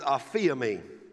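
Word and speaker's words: aphiemi (ἀφίημι): It's pronounced aphiemi.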